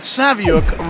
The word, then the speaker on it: Saviuk: Hello, this is Alex Saviuk for 11 O'Clock Comics, and that Saviuk rhymes with book.